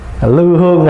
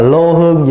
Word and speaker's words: lư hương: Lư hương, là lô hương gì một chữ. Ngày xưa bản kinh ngoài Bắc là lô hương xạ nhiệt, sau này mình phát âm là lư hương xạ nhiệt.